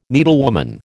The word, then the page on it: needlewoman: American English